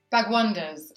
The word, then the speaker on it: Bhagwandas: Grace Timothy, author, beauty journalist, mum, extraordinary human, and my other buddy, Anita Bhagwandas. Did I say that wrong already? How do you say it? – I mean, yeah, that is basically it. It's Anita Bhagwandas. – Bhagwandas.